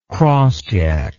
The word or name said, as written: crossjack